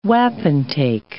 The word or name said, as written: wapentake